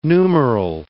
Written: numeral

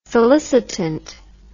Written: solicitant

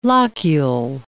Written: locule

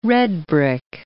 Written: redbrick

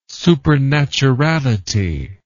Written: supernaturality

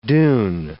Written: dune

